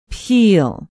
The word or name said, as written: piehl